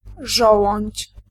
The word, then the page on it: żołądź: [ˈʐ̠ɔwɔ̃ɲt͡ɕ]